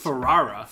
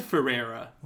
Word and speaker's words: Ferrara: And Conan O'Brien said Ferrara. – Did he? – So I think that's how it's pronounced. – Ferrara. – Ferrara. – But I've always said Ferrera.